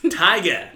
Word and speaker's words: Tyga: I don't even know who Tyga is. – Okay. Thinking of not knowing who things are. – It's just Tiger spelled with a Y. – I hate Tyga.